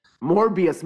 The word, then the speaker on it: Morbius: Venom 2, que hizo Morbius, Mobius. Morbius, Mobius de fucking. Sí, dije Mobius. Me encanta ah, que you said it right the first time y dijiste Mobius. – Ah, no, es que no dije Morbius pensando en Morpheus.